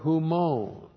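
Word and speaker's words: humon (ὑμῶν): For the purpose that our praise, and then our praise, ta huper humon.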